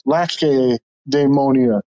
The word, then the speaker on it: Lacedaemonia: I think that it's a hard C. – Lacedaemonia.